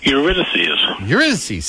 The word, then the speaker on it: Eurydice: So who's directing, is this Eurydice? Am I saying that right? – Eurydice. – See, I knew Eurydice. I was to say it wrong.